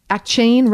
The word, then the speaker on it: Achane: So I'm looking at Pro Football Reference right now. They say Achane. A-Y-Chan. – Achane. Achane. Achane. So Achane. Achane? – Achan, A-Y-C-H-A-N. That's how they have it officially like listed phonetically for pronunciation. So I'm going with Achan.